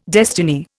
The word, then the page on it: destiny: Indian